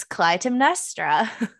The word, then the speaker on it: Clytemnestra: It's, watch me struggle, it's Clytemnestra by Costanza Cazzotti.